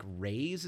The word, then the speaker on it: Rhys: Matthew, I think it's like Rays is how you say his last name. It's like R-H-Y-S.